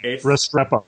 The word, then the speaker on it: Restrepo: The first is called Restrempo, which is spelled Restrepo.